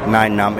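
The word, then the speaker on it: Nien Nunb: Hi there, this is Mike Quinn, and I played Nyan Num in the Star Wars movies, and you're watching or listening to Below the Belt.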